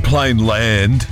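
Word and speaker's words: Plainland: I always call it Plain Lands, but it's actually Plain Land, which doesn't sound right. – There's no S. – Yeah, there isn't an S, but we do that.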